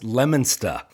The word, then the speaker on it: Leominster: Well, in fact, we found out that Matt is originally from Leominster, Massachusetts, and I only know how to pronounce that because I went to design school at Fitchburg State University, right next door. So if you're a local, it's technically pronounced Leominster.